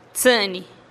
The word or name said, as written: tsï'nih